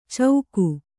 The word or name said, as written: ಚೌಕು